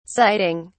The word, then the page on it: siting: British English